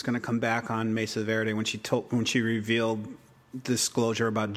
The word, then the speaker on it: Mesa Verde: She takes that Mercedes girl. I'm not saying that right. Mesa Verde. I said it wrong.